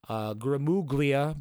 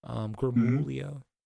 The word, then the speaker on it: Gramuglia: Grimuglia, but the G is silent. I only say that because it kind of just helps for me. Grimuglia.